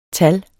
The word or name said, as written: TALLENES